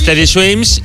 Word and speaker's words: Teddy Swims: Se llama, a ver si lo digo bien, Teddy Swim. – Es Teddy Swims y esta es una canción que hace con Tiago.